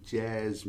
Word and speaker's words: jazz: See, his name abbreviated is J-A-S, and you pronounce it as jazz.